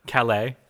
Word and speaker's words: Calais: Calais. – Uh-huh. Calais to Denver.